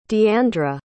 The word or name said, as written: diandra